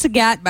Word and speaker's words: Zagat: Which is pronounced Zagat, by the way. You could think of it as Zagat.